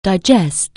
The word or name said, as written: digest